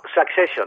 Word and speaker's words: Succession: Que es diu Succession. – Sensation. – No, Succession.